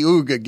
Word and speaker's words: Wooga: You're the first person I've heard pronounce Wooga Poplar's name that way. Just Uga.